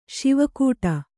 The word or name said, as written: ಸವಕಟ್ಟು